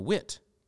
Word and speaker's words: Witt: But I thought she was Katarina Witt because with a W, and I didn't know that W's are pronounced with a V in German.